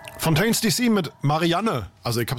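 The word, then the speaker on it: Fontaines D.C.: Und du hast es wirklich falsch ausgesprochen, ja, weil es ist Fontaines DC mit Marianne.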